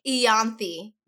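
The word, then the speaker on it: Ianthe: There's two ways to pronounce it. Two ways. – Yenith or Ianthi? – Yes. – Ianthi. What the fuck? – Yeah.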